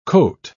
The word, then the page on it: cote: British English